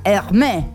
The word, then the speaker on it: Hermès: Hermé, se escribe Hermès con acento invertido en la E, pero se pronuncia Hermé, la casa francesa de diseño y de moda que se fundó en el año 1837.